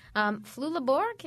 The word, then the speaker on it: Flula Borg: Flula Borg, is that how you say it? – Yes.